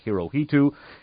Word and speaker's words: Hirohito: We also took a look at the fact that Emperor Hirohito himself, by the way, Emperor H-I-R-O-H-I-T-O's name is usually mispronounced by Americans, Hirohito. His name is actually pronounced Hirohito. That's the correct pronunciation.